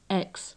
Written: x

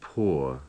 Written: pour

